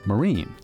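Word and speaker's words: Marín: It was now spelled M-A-R-I-N, with an accent mark, which in Spanish is pronounced Marine. – And when the Americans came in, they saw this name Marine, and they didn't notice what the accent meant in Spanish, and they just pronounced it Marin.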